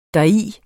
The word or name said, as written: deri